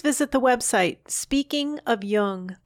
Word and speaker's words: Jung: Be sure to pronounce Jung with a hard J.